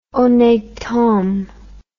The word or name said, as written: honnete homme